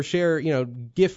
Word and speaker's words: GIF: It's pronounced GIF, like the peanut butter, but like you might as well be calling Pete the N-word over here.